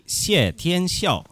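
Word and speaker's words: Xie Tianxiao (谢天笑): Puoi ripetere fuori che io la pronuncia cinese, il nome di questo artista che abbiamo appena ascoltato? – Lui Xie Tianxiao. – Ok, perfetto.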